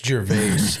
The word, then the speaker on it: Gervais: Well, I didn't want to just, you know, stop and be like, actually, it's Gervais. So I decided to say his name and hope you heard me. – I get confused because I have a good friend named, spelled the same way, and it's Jarvis. Just Jarvis. Same exact spelling. So it's either Jarvis, Gervais. And I flipped the coin.